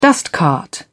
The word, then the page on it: dustcart: British English